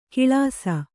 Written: ಕಳಸೆ

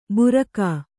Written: ಭೂರ್ಗ

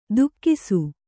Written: ತುಕ್ಕಿಸು

